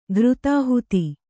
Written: ಘೃತಾಹುತಿ

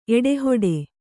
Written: ಎಡೆಹೊಡೆ

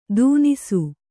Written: ದೂನಿಸು

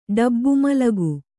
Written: ಡಬ್ಬುಮಲಗು

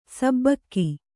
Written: ಸಿಬಿಕೆ

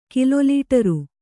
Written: ಕಿಲೊಲೀಟರು

